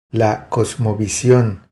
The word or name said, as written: La cosmovision